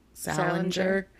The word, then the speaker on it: Salinger: Salinger? – Yeah.